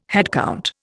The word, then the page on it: headcount: Indian